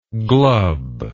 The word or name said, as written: Glubb